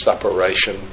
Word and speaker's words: suppuration: and I think the word is suppuration. I wouldn't want to try to spell it, but that's the pronunciation, I think.